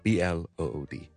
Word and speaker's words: blood: 佢俾个例子咧，就系咧血啊，blood B L O O D blood。佢有时会可能读成有啲类似。 B-U-D, bud, 咁樣。